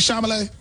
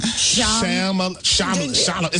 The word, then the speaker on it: Chalamet: Is it Timothy Chalamet? – He said Charlamagne.